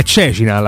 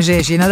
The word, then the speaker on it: Cecina: È Cecina l'accento, non è Cecina ma No, no, è Cecina, Cecina, Cecina.